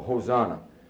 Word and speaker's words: Hosanna: The final word in Kittel is Hosanna, because in Greek, the last word in the alphabet is omega, and Hosanna is a long O. H is just a mark for breathing. You breathe in that long O, Hosanna.